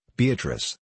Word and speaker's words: Beatrice: Note, Beatrice. I use this word, as it is pronounced in the Italian, as consisting of four syllables, of which the third is a long one.